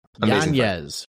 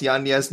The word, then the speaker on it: Yanez: Yanez. We're going with Yanez. – Has he said he wants Yanez now? – That's what it is. That's his that's how it's supposed to be pronounced.